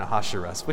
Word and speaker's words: Ahasuerus: that's what I'm going to call him because it's a lot easier to say than Ahasuerus, which is the correct pronunciation.